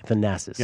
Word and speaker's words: Thanasis: I think it's Thanasis. – Oh, did you say his name? Matt didn't earlier. I'm sorry. Thanasis. – Yeah. – Yes. – You could be wrong. – Pardon me. – Yes, Thanasis.